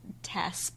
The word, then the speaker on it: TAS: Right, and it rhymes with podcast, sort of, if you say it quickly. – Kind of. – Say it fast. – TAS podcast.